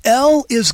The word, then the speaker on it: Eli: And it's pronounced like a long E. So El is God, Eli is my God.